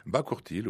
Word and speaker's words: Bakourtil: Terre, c'est T-E-R, dans une cité, un village, au milieu d'un lac qui s'appelle Bakourtil. C'est comme ça que ça se prononce ? – Bakourtil, oui, c'est